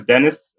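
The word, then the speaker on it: Denis: Yeah, so my name is Dennis, Dennis Odera. You can call me Dennis.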